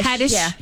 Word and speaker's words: Haddish: Haddish.